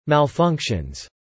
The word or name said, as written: malfunctions